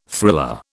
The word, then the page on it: thriller: British English